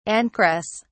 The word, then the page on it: ancress: British English